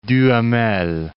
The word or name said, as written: Duhamel